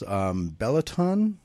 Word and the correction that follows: Benetton: Films, is that correct? – It's Benetton Films and Hillin Entertainment.